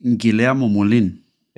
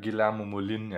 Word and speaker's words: Guillermo Molin: Og jeg tror, han udtales Guillermo Molin. – Ja, Guillermo Molin, ja. Yes.